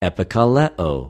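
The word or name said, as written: ἐπικαλέω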